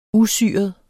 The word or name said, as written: usyret